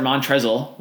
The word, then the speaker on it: Montrezl: Say you'll start Montrezl with an L. No silent L. There's an L there.